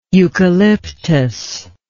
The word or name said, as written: eucalyptic